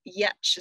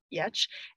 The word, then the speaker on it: Giertz: And then just on the more searchable, like who would inspire me, there is a woman, Simone Yetch is how you say her last name, but it's spelled G-I-E-R-T-Z, Simone Yetch.